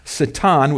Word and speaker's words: Satan: In verse 6, the angels, including Satan, that's the Hebrew pronunciation, I'll probably use that from time to time, Satan,